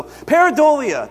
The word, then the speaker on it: pareidolia: So pareidolia, that's how this word is pronounced.